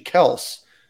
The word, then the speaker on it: Kelce: Their last name is actually Kels, and they thought it would be appropriate at the Super Bowl to say it's actually Kels and not Kelce.